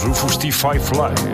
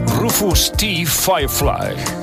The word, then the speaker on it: Rufus T. Firefly: Claro, claro, yo lo he dicho mal. – Claro, y él solo quería decir. – ¿Cómo se dice? – Rufus T. Firefly.